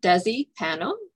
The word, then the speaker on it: Desi Pano: I'm here with my very special friend today, Desi Pano.